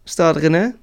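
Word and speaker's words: Stade Rennais: Toch, zo zeg ik het goed hoor? Staat René? – Ja, ja heel goed.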